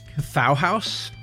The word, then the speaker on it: Cthauhaus: the first act, I think it's pronounced Cthauhaus, and it rhymes with Bauhaus.